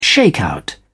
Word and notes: shakeout: British English

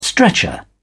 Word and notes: stretcher: British English

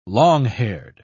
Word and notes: long-haired: British English

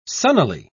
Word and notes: sunnily: British English

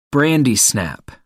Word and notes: brandy snap: American English

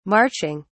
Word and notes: marching: British English

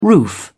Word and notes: roof: British English

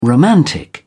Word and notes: romantic: British English

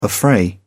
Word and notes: affray: English; British English

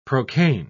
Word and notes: procaine: English; British English

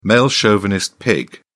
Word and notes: male chauvinist: British English

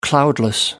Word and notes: cloudless: American English

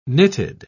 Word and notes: nitid: British English

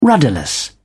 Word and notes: rudderless: British English

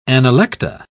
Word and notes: analecta: British English

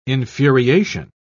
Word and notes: infuriation: British English

